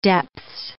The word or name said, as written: depths